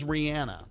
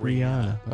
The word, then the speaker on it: Rihanna: My biggest concern is it Rihanna or Rihanna? – She says Rihanna.